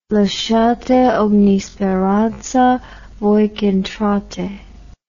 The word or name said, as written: lasciate ogni speranza, voi ch'entrate